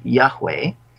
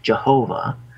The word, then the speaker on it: Yahweh: Now, this is pronounced in Hebrew, Yahweh. Some people have pronounced it Jehovah.